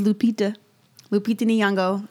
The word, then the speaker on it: Lupita Nyong'o: And that was Lupita Nyong'o.